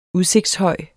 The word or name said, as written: udsigtshøj